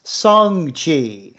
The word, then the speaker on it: Shang-Chi: Okay, this may just be my my upbringing, but I've called this guy Shang Chi for most of my life. Now, according to Entertainment Weekly's article, it's actually pronounced Song-Chi. So I have no earthly idea.